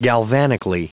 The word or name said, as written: galvanically